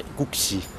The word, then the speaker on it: Guksi: Et Gukshi, ça s'écrit G-U-K-S-I. – C'est ça, oui. Gukshi.